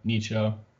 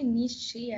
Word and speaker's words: Nietzsche: I've heard th- I've heard people say Nietzsche also like because in German we don't say like if you have e at the end you don't say e you say er so like that's why you say danke.